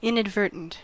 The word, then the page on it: inadvertent: American English